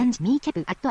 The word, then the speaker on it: ミーキャブ: ミーキャブアットアナザーパートオブスピーチアンドモルフォロジカルアナライザーミーキャブと読み上げます。